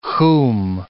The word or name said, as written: khoum